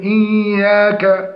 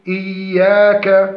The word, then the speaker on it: إياك: التحقق من خروج الياء من مخرجها وسط اللسان لا الخيشوم، مع مراعاة صفة الرخاوة فيها وعدم نطق الألف التي بعد الياء إياك. والصحيح إعطاء حقها من النطق بمقدار حركتين والتكلف في إظهار الهمس في الكاف، والصحيح ترقيقها وعدم تكلف إظهار الهمس بها لأن الهمس لا يظهر إلا بالساكن، فنقول إياك، وهذا هو الصحيح.